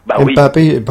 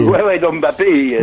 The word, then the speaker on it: Mbappé: La France comme équipe, l'avenir est très prometteur, pareil, avec MKB. Mbappé, excusez-moi, je l'ai mal prononcé. – Mbappé, ouais.